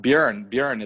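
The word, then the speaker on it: Björn: Bjorn is the right Swedish pronunciation, right? – Yes.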